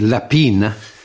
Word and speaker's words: Lapin: Well, I mean, you pronounced it in the French way, Lapin. I think it's actually Lapine.